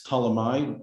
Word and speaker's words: Ptolemy: In English, it's Ptolemy with a silent P at the beginning.